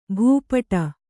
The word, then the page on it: ಭೂಪುಟ: bhū puṭa